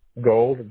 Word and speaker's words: Gold: Gold, G-O-L-D.